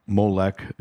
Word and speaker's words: Moloch: And you will read that those followers, those Canaanites, would basically, on the altar of Moloch, Molech is another pronunciation, others would say Baal, that they would do child sacrifices to their god.